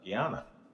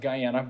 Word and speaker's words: Guyana: So, historical day, not only face-to-face, but you're our first guest on the podcast born in Guyana. – I was born in it's actually pronounced Guyana, in South America.